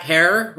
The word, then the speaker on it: Herer: We really hope you enjoyed our live episode about the life and times of Jack Herer, Rhymes with Terror, which we dropped on the morning of 420.